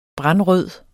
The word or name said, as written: brandrød